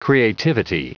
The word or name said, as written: creativity